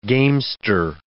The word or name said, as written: gamester